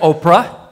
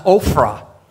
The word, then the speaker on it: Ophrah: It sounds like Oprah, but I think it's pronounced Ophrah. Right? Like a F, Ophrah.